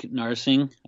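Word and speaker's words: Narsingh: I like Narsing. I believe that's how you say it.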